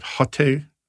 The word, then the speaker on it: Haute: Haute cuisine. Haute. H-A-U-T-E, Haute Cuisine.